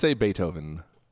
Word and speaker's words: Beethoven: Sorry, I was listening to a video that says how to say Beethoven.